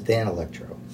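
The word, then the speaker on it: Danelectro: I said, what was with that little Danny Electro guitar you gave me? He goes, it's pronounced Dan Electro.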